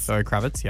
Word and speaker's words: Zoe Kravitz: Now, I spoke about him recently because his ex, now I'll say her name wrong as I always do, Zoe Kravitz. – Zoe Kravitz, yeah. – Oh, I got it right.